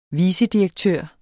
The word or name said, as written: vicedirektør